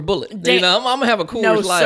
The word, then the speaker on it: Coors Light: And you don't even say that right. It's a Coors Light. That's how you gotta say it.